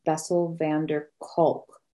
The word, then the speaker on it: Bessel van der Kolk: I'm gonna admit that I weep every time I read it, but it's called The Body Keeps the Score by Bessel van der Kolk. He's a I don't know if I said that right. Actually, I'm pretty sure I didn't. Bessel van der Kolk, MD.